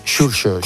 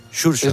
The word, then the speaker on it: Xurxo: ¿Cómo se pronuncia exactamente? – Xurxo. – ¿Es como una S normal? – No, como el X en inglés. – Sí, es casi inglés. Xurxo. Es la X inglesa.